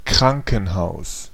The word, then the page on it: hospital: /ˈhɑspɪtɫ/; /ˈhɒspɪtəl/